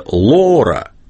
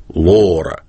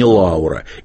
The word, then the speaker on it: Лора: Все-таки почему английская «Лора», замечу, именно так Набоков, сам автор, писатель, произносил имя своей героини дома, в быту. Он называл ее Лора. Вы скажете, он называл ее на английский манер? Очень может быть. Но никакая не Лаура.